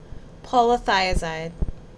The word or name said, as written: Polythiazide